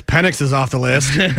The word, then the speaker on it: Penix: Oh, is that how you say his name? – Yeah, Penix. – Mm-hmm. – Penix. – Yes. – More on the X and less on the IS.